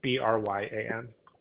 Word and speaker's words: Bryan: Y, Bryan.